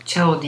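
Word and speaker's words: Zhao: Chloe Chao'nun, Chao diye telaffuz ediliyormuş. Onu da ben yeni öğrenmiş oldum.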